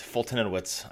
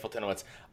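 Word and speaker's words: Foltynewicz: Oh man, I can never say this guy's Fultonowicz. I think is how you say it. Yeah, Mike Fultonowicz.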